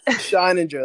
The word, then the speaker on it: Scheininger: Scheininger.